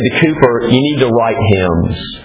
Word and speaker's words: Cowper: I know it looks like Cowper, but it's pronounced Cooper.